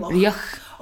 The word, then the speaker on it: Reoch: It's that sort of soft CH though that nobody can ever say at the end of my name. Reoch.